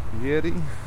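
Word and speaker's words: Jiří: Anyway, in Czech, it's Jiri, J-I-R-I with an accent on the I.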